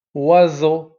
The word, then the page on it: oiseau: son wa